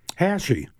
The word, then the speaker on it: Hachey: I could be mispronouncing it, too. But Haechi. – Haechi? – Yes. – The boxers. – Yes. Thank you. – H-A-C-H-E-Y. Yeah. – Thank you for that.